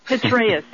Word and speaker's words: Petraeus: Petraeus, yeah. P-E-T-R-A-E-U-S.